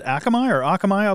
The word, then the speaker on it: Akamai: Now, you mentioned, I think it was Akamai, I believe it's pronounced.